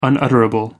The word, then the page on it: unutterable: American English